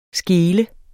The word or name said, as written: skel